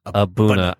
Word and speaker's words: A'Bunadh: Aberlour Abunna. I'm impressed that I've freaking said it right more than once. – Abunna.